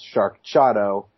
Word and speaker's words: Sharkchado: So I'm assuming it's spelled like Sharknado, but it's a combination of Jeff Samardzija and Manny Machado, so it's Sharkchado. So I will give that a soft C, I think is what I would say.